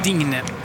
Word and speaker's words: Digne: Lucas Digne slutar också på E men uttalas inte så där som Mbappé E.